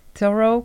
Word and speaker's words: Thoreau: Sie lebt in einem kleinen Häuschen und der Hintergrund hinter dieser Geschichte ist, dass Annie Dillard hat studiert, hat ihre Arbeit über den Thoreau geschrieben. Habe ich es richtig ausgesprochen? – Ja, ja.